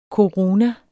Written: koruna

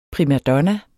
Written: primadonna

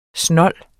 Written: snolds

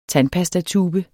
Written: tandpastatube

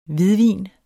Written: hvidvin

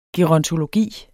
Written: gerontologi

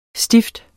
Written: stift

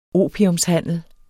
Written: opiumshandel

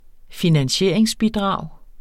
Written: finansieringsbidrag

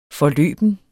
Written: forløben

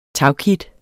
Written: tagkit

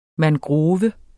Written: mangrove

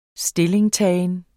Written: stillingtagen

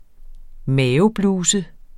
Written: mavebluse

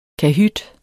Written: kahyt

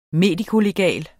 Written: medikolegal

